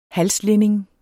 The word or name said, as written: halslinning